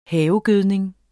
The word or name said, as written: havegødning